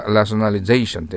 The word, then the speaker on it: rationalization: よく見ると、最初が ration と書いてあるの、 ration と読めそうですよね。ラショナル、合理的なという形容詞ですよ。それから、ラショナリゼーションって、 L-I-Z-A-E とかなる、ライズとか、 I-Z-E。